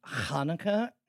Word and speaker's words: Hanukkah: That's G-I-L-T. I ask you right now, is it Hanukkah? – First off, it's pronounced Hanukkah, and also yes.